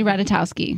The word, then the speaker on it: Ratajkowski: Emily Ratajkowski.